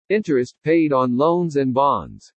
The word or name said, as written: INTEREST PAID ON LOANS AND BONDS